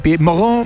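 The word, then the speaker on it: Pierre-Mauroy: Pied Moró, si quieren la pronunciación francesa.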